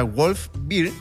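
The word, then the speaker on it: Wolves Beer: Bueno, se pronuncia Wolf Beer. – Mira, Wolf. – Pero se escribe Wolves por... para que me busquen en Instagram.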